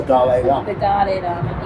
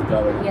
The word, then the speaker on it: Dalai Lama: The Dalai Lama, yeah. – Totally, it's the Dalai Lama.